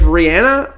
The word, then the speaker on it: Rihanna: Listen, if Rihanna or, which by the way, guys, that is how she says that she says her name. So that is the correct way to say it.